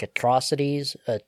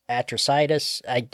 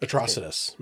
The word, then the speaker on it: Atrocitus: It's like Atrocities, Atrocitus. Spelled a little bit weird. I always read it as like Atrocities.